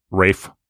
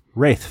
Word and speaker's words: Ralph: He pronounces it Rafe. – Wraith Fiennes. – Wraith. It's not Wraith. – Wraith. – Maybe for one of our Halloween. Rafe. – Wraith. – Rafe. – Wraith. – No. Rafe. – Wraith. – No. There's an F sound at the end, not a T.